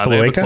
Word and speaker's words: cloaca: We know that they have a biology at least somewhat similar to Salarians because they have cloacae or is that how you pronounce it? Cloaca? – uh, cloaca? cloaca. – Yeah.